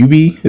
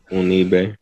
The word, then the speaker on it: Unibe: Like in 2014, you studied abroad at, am I saying this right? Is it UB? Is that how you say it in Dominican Republic? UB? – Oh, Unibe. – Yeah. I mispronounced some of these words. I'm sorry. – No, it's all good. That's like an abbreviation. Unibe, Universidad Iberoamericana.